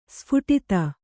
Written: ಸ್ಫುಟಿತ

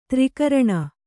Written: ತ್ರಿಕರಣ

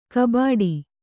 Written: ಕೊಬ್ಬಟ್ಟು

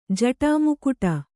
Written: ಜಟಾಮುಕುಟ

